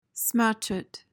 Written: smatchet

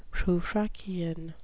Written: prufrockian

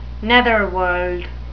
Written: netherworld

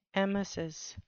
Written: emesis

